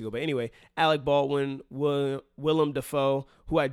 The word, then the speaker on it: Willem Dafoe: I thought it was William Dafoe, and I'm sure a lot of people listening also thought it was William Dafoe, but it is Willem Dafoe.